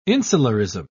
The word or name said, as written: insularism